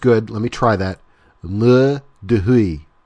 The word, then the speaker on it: Mur de Huy: Okay, so now the next one is, and this one I really messed up, I think I called it the Mur de Huy.